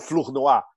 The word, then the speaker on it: Flournoy: O outro que está no gabinete, que é uma super ideóloga do Pentágono, a Michelle Flournoy, ou Flournoy, se você pronunciar canadense e francesa.